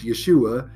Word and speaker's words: Yeshua: It is the same name, Joshua, and that gets pronounced Yeshua in Hebrew.